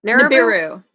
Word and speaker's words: Nibiru: How do you say it? – Nibiru.